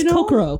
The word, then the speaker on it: Kokoro: So it's Kokoro. – Kokoro. Oh, I say it Kokoro, but I'm French. So that's, I mispronounce words all the time. I put the accent on the wrong syllable in English.